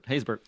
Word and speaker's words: Haysbert: Haysbert.